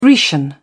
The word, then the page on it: Grecian: British English